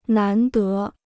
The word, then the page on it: 难得: nán dé